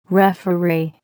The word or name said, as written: referee